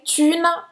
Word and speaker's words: thune: E aí thune, presta atenção de novo aqui na pronúncia, tá? É esse u francês. com Una. o biquinho.